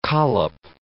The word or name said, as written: collop